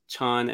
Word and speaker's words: Chan: But it's from Gillian or Jillian, and it said, are the words Chan and Zen interchangeable? – Oh. The relation of them is this. There's a Chinese ideogram, and it in China, you pronounce it Chan.